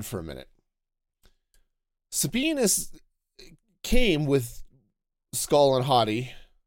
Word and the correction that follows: Hati: Hati, I think is how they actually pronounce it in the show.